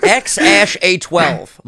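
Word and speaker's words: X Æ A-12: And he's like, X. And then the AE is Ash and then A12. And I was like, what the fuck? – X, Ash, A12, Musk.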